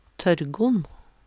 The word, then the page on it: Tørrgonn: Numedalsmål